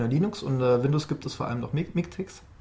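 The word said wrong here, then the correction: MiKTeX: Äh, MIGTech, das habe ich auch falsch gesagt.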